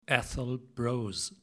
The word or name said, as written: Atholl Brose